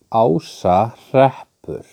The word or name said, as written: Ásahreppur